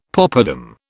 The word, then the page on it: poppadam: British English